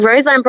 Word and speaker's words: Roseland: Ro- Roseland Ballroom.